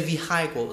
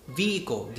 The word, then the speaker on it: vehicle: Like the word uh, vehicle, a lot of people say vehicle or something. Come on, vehicle, the H letter is silent.